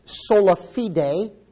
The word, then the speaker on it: sola fide: It's sola fide is the correct way to pronounce it.